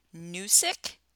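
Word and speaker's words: Gnoosic: And it is a website called Noosic, and that is with a silent G, G-N-O-O-S-I-C.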